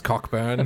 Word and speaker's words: Cockburn: do you go to Coburn? – It's pronounced Cockburn.